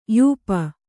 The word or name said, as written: ಯೂಪ